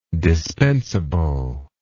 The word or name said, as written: dispensible